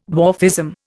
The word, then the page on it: dwarfism: Indian